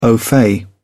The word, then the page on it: au fait: British English